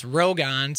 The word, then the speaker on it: Rogan: So our name is actually pronounced Rogan, so it's like Rogue.